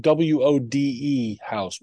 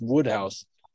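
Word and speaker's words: Wodehouse: It's spelled Wode House, Wode House, but it's Woodhouse.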